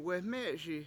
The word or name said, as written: weME7eSi